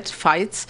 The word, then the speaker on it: Veinz: My name is Brigitte, and the last name is spelled V-E-I-N-Z, Fight.